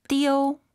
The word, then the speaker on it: 丢: 띠우 아니죠? 아이유 사이에 오 넣어서 잃어버리다 발음해보세요. 먼저 여러분 하나 둘 셋. – 띠우.